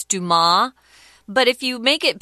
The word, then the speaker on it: Dumas: Excuse me, Dumas. The S, is, I guess, is silent in French there.